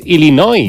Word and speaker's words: Illinois: Se pronuncia Illinois. – Sí, perdón, sí. – Illinois. – Pues yo digo Illinois, y no entendemos todo. Porque como diga Illinois, nadie lo entiende.